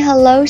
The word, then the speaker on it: hello: 好，你看一下文本呢，我又把它的这个音标标注出来，它呀其实就是呵呵呵呵呵呵加上一个 low 啊， hello hello，但是哦它还有一个读音，它可以读作，不读作呵呵呵，读作嘿嘿嘿，哈，其实都像在笑那个声音呢， hello hello。